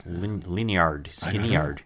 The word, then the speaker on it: Lynyrd Skynyrd: How about Lynyrd Skynyrd? – Lynyrd. Skynyrd. Yeah. We'd get in arguments how to properly pronounce that. – Lynyrd? – A cousin of mine finally said, dummy, it's just Lynyrd Skynyrd.